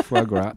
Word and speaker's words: foie gras: You had some good foie gras. Is that how you say it? – Yeah, foie gras.